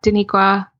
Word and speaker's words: Danikqwa: And then because my name is spelled so uniquely, I also have at Danikqwa on Twitter and at Danikqwa on Instagram.